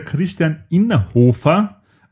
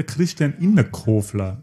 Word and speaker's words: Christian Innerhofer: Es ist der Christian Innerhofer und nicht der Christian Innerkofler, wie ich gesagt habe.